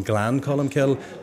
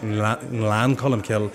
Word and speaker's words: Glencolumbkille: And I remember the first the first week of getting the job, I was pronouncing Glencolumbkille and I was going Glan Columkill, but to get the tongue up to the roof of the mouth and get Lan Columkill where there's a wee n before the G, it's it's uh, there's a lot of wee nuances there that uh, that I still work hard on.